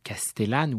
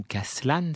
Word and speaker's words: Castellane: Il se trouve que, bien sûr, la famille de Castellane, ou Caslan, si on maintient la prononciation historique, était une famille que j'avais souvent eu l'occasion de croiser dans mes travaux.